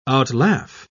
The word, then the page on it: outlaugh: British English